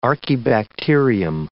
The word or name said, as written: archaebacterium